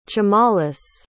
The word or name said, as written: chimalus